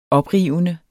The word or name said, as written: oprivende